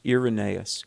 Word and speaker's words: Irenaeus: One was a man named Irenaeus, or sometimes pronounced Irenaeus.